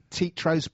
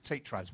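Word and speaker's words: Teatros: and that's spelt T-E-A-T-R-O-S-B-A-R. Tetros Bar, and they're in Canterbury. – Is that Tetros or is it Tetros? – Well, I pronounce it Tetros, but I'm from South London. You can pronounce it how you like, mate. Tetros.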